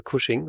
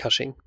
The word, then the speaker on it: Cushing: Er heißt natürlich Peter Cushing und nicht Cushing.